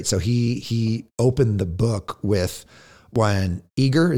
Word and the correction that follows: Iger: is that how you say it? Disney CEO Iger. – Iger. Iger.